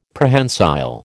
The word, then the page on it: Prehensile: American English